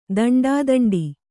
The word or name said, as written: ದಂಡಾದಂಡಿ